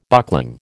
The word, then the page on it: buckling: American English